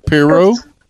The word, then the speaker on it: Perriot: Uh, it's pronounced Piro, is um, the proper pronunciation. I used to say "piro" too. – Um, so, Piro? – Yes.